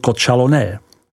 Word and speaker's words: Côtes Chalonnaises: Jako první navštívíme nejmenší burgundský region Côtes Chalonnaises, vyslovuj Chalonné.